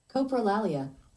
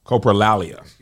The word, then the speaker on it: coprolalia: This is pronounced Coprolalia. – Coprolalia.